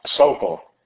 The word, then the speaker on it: Socol: Socol.